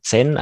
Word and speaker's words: Zen: Es gibt ja äh, Zen, also Z-E-N geschrieben, Zen, aber Zen spricht man das aus, ist ja ein Begriff, der sehr bekannt ist.